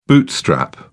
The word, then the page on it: bootstrap: British English